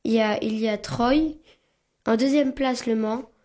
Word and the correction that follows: Troyes: Il y a Troyes. – Hein? – La ville se prononce Troyes. – Hein? – Voilà. – Troyes.